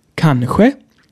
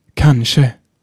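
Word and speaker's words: kanske: Klingt beides für mich gut und auch für andere Schweden. Es steht sogar im Wörterbuch. Die beiden, äh, nicht Schreibweise, sondern äh, Aussprechweise, Aussprachen. Die beiden Aussprachen. Warte mal, ich muss mal äh, äh ins Wörterbuch gucken. Langenscheid sagt Kansche. Also benutzt, was ihr wollt.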